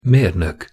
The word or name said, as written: mernok